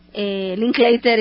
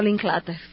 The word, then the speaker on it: Linklater: Entonces se pronuncia Linklater y no Linklater.